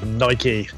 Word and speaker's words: Nike: Of course, there's going to be a pronunciation issue here, but that is Nike. – Yes, there is. You see, I would say Nike, but we'll let that go.